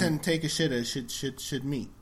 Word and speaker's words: Takeshita: Yoshie Takeshita.